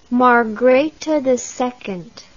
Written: margrethe ii

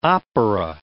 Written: opera

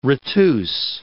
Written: retuse

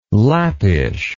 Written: Lappish

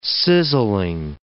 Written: sizzling

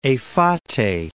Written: Efate

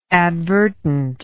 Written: advertently

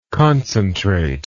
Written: concentrativeness

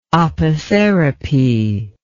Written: opotherapy

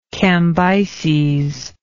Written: cambyses

